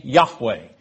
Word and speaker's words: Yahweh: And it is pronounced by canons of acceptance today, Yahweh. Y-A-H-W-E-H.